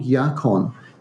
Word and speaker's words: yacon: It's called yacon, and it's spelled Y-A-C-O-N.